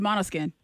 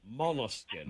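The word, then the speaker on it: Måneskin: And so, we're going to see that the name of the band is spelled ManeSkin. What's the name of the band? – It's Monoskin. – Monoskin. – Monoskin. That's correct.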